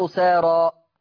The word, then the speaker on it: أسارى: أنا ماليش دعوة إن الكلمة مكتوبة إيه، أنا يهمني إن الكلمة اتنطقت إيه، بتتنطق أساري ولا تتنطق أسارى؟ يبقى نطقت ألف أسارى، يبقى هيقلل الورش، هيقلل الألف عشان الألف دي في آخر الكلام بعد راء أسارى.